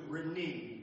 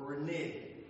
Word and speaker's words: renege: That's where neither is where we get the word to renege or renege, depending on how you say it.